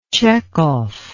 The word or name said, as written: tchekhov